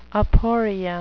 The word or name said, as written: aporia